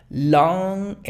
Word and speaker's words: long: La G, no te esfuerces en la G. Es long, long, long. De hecho, el sonido NG no se pronuncia sin la G. O sea, no digas solamente long. Tampoco trates de pronunciar la G, que digas long. No. Pero la pronunciación NG es long.